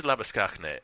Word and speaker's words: Labuschagne: Well, I sort of feel awkward about it because it is Labuschagne, and that's how it's pronounced in South Africa. And basically he's gone, look, Australians can't handle it, so I'm just going to abandon my native pronunciation and just make it something easy for these idiots to go along with.